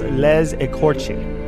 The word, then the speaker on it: Les Écorchés: It's Les Écorchés. – Les, you know, the way you said that almost sounded French. – It is French. It is a French phrase.